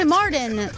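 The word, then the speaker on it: Marton: No, si dice Morden. Sandy Morden. – Sì, Morden. – Morton.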